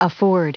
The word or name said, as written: afford